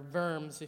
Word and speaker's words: Worms: Diet of Worms, or Verms, if you're doing the nice pronunciation.